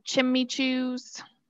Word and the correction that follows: Chimecho: Either Chimecho or Chimico, and I think it's Chimecho.